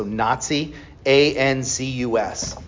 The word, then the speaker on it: Nazianzus: And then the third of the Cappadocian fathers is Gregory of Nazianzus. That's spelled N-A-Z-I, so Nazi, A-N-Z-U-S, Nazianzus.